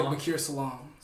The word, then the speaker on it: Baaqir Salaam: Did I pronounce that right? – Yeah. – Or is it Salaam? – Salaam. – How do you say it? – I say it Baaqir Salaam.